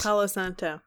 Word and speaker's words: Palo Santo: Palo Santo. Pa- I don't know why I can't say it right. Santa Paul. It's because you're used to like Santa Ana.